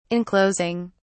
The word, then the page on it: enclosing: British English